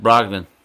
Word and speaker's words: Brogdon: I forgot his name is pronounced Brogdon.